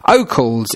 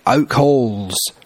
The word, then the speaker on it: Oakalls: Yes, Oakles is spelt O-A-K-A-L-L-S, Oakles, but it's been spelt on the road sign with a silent H, Oak Halls. The H doesn't exist in Oakles.